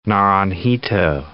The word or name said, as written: Naranjito